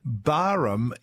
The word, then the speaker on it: Barham: And Fee in St Kilda says, Dear Pat, FYI, Barham is said like Barramundi with a silent H.